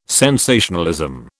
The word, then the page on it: sensationalism: British English